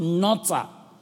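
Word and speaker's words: notar (נצר): Very interesting, that word keep, it's the word N-A-T-S-A-R, pronounced notar. N-A-T-S-A-R.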